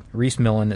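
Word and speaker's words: Rhys Millen: Reese Millen, it's spelled R-H-Y-S, and then Millen is M-I-L-L-E-N, and the one that we're talking about is where Reese Millen, it says Reese Millen smashes the Pikes Peak record.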